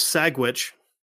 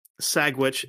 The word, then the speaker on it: Sagwitch: Sagwitch, Sagwitch, Sagwitch. – Okay. – You think I made that up, but I didn't. – It's almost like, you know, trying to pronounce sandwich, but it was like Sagwitch.